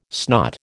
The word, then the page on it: snot: American English